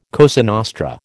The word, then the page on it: Cosa Nostra: American English